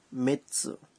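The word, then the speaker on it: মিৎসু (三つ): ঠিক তাই যেমন তিন হচ্ছে সাম কিন্তু সু যুক্ত হলে এটির উচ্চারণ হয়ে যায় মিৎসু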